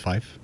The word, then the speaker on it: Fyffe: Over Fife, if that's how you say it. – Fife? – F-Y-F-F-E. So I think it's Fife. – I go with Fife.